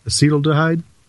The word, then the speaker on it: acetaldehyde: You're pronouncing acetaldehyde. – Acetaldehyde? – As acetaldehyde, which is there's no acetaldehyde. – Right. There's no we're throwing in an extra syllable there, which is correct.